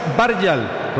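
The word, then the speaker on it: Bergvall: Lucas Barjal, con acento en la primera A, y Anthony Elegant, el 7 para Barjal y el 11 para Elegant en el equipo de Suecia.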